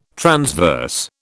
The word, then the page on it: transverse: British English